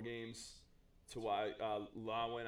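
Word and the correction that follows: Lewan: I don't know how to pronounce his last name. – Taylor Lewin.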